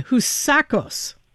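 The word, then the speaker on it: Housakos: I'll take another run. Houssacis. – Perfect.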